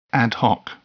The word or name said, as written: ad hoc